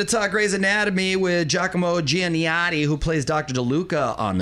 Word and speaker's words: Giacomo Giannotti: Giacomo Giannotti.